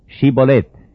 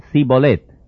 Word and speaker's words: Shibolet: Si él respondía no, entonces le decían: Ahora pues di Shibolet. Y él decía Sibolet, porque no podía pronunciar correctamente.